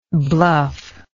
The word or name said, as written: bluffer